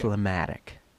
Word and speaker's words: phlegmatic: Did I pronounce that correctly? Phlegmatic? – Yes, okay. Phlegmatic. – Phlegmatic. F-L-E-M-A-T-I-C.